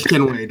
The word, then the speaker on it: Kenway: No, it is Kenway.